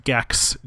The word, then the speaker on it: Gecs: And I have a crew with some of my buddies from college called Gex, G-E-C-S, pronounced like the classic PlayStation 1 character.